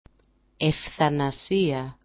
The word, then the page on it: ef8anasia: ef8anasi-a